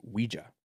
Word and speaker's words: Ouija: 28 girls hospitalized after using Ouija board at school, which I find out the correct pronunciation is Ouija.